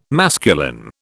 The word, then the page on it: masculine: British English